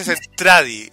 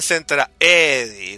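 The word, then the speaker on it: Zentradi: No, mira, se dice, en la versión japonesa se dice Centradi, y en Robotech le dicen Centraedi, con una E.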